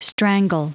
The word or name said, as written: strangle